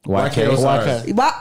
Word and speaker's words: YK Osiris: YK Osiris. – Oh, that's how you say his name? – YK yeah. Osiris. – What were you calling him? I thought it was You Know Osiris.